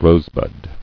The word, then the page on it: Rosebud: rose·bud